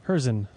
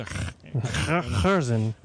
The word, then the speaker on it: Hurczyn: Hurczyn. – Hurczyn. I thought there was a Hurczyn.